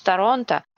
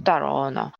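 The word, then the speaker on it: Toronto: почему кто-то произносит Торонто, а кто-то произносит Тороно,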